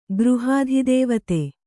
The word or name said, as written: ಗೃಹಾಧಿದೇವತೆ